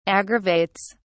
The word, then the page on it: aggravates: British English